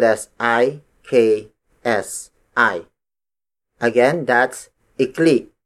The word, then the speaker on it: ikli: The opposite of haba is ikli. Ikli. Ikli. Ikli is spelled as I-K-L-I. Note the glottal stop at the end. Ikli, not ikli.